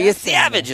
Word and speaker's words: Yesavage: I buy Yesavage as a pronunciation.